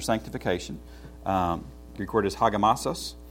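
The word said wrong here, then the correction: Hagiasmos (ἁγιασμός): I mispronounced that. Hagiasmos.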